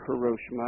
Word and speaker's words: Hiroshima: The title is Rain in Hiroshima, and it doesn't matter whether you say Hiroshima or Hiroshima. I tend to say Hiroshima. It's slightly closer to the way the Japanese pronounce the name.